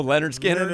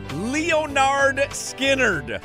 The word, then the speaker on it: Lynyrd Skynyrd: Lynyrd Skynyrd does not rhyme.